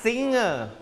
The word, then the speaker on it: singer: เด็กๆครับไม่ใช่ singer นะ no ไม่ใช่เสียงเกอนะครับ ng ออกเสียงเงอเพราะฉะนั้นแล้วออกเสียงว่า singer